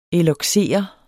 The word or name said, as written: eloxere